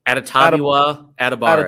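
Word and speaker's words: Adetomiwa Adebawore: And then you have Kalijah Kancey, Brian Brisee, and Adebari as How do you say that first name? Adetamiwa? – Adetamiwa Adebare. – Adetamiwa Adebare.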